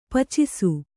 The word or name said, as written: ಪಾಚಿಸು